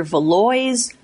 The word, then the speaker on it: Valois: It's spelled V-A-L-O-I-S. Most people in the neighborhood will say either Valois or Valois, and then they'll fight about which way you should say it.